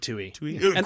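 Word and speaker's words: Twohy: Oh, and Warlock was written, and David Toohey? – I believe you pronounce his name David Toohey.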